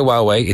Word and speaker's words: Huawei: It's the Howie one that we call Howie. I don't know how to pronounce it properly. It's Huawei.